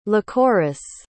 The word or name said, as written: lycoris